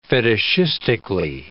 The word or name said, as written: fetishistically